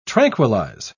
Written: tranquilize

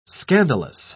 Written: scandalous

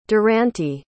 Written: durante

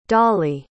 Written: doli

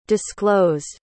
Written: disclosed

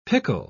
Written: pickle

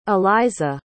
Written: elyza

